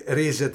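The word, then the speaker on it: Rize: Και ρίζε το προφέρουν τώρα.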